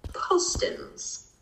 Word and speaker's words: Postens: Well, My Plates does free layaway, and this is for Matt Postens. Matt? – Postens, I think is what he was saying. – Postens, yeah. – Yeah, yeah. Okay. – Yeah. It's with the long O. Po.